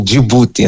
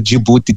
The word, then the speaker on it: Djibouti: Agora, indo diretamente à questão, dizer que na nossa pronúncia aqui em Moçambique, o nome Djibouti é mesmo Djibouti, então vão ficar à vontade eu dizer Djibouti diretamente, como nós dizemos aqui em Moçambique.